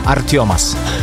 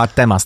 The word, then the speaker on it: Artemas: Artiomas. – Wow, naprawdę. – No, tak się wymawia, wiesz, że ty byś powiedział Artemas, tak? – Tam powiedział, no.